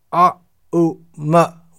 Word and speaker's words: Om: In other words, om is pronounced a, u, ma, when these three syllables are joined together, a, u, ma, then we get aum. Oh, it's written in English, A-U-M.